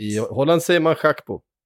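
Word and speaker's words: Gakpo: Cody Schack på. Som det egentligen ska uttalas. – Nej men sluta. – Schack på. Han är holländare. Det ska vara Schack på.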